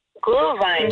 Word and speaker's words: Glühwein: Glühwein.